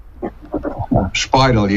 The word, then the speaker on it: Speidel: Have I got your surname pronunciation correct? – Speidel, yes. – Speidel. Where's that originally from? Is it It's German, is it? – German, very much so, yes.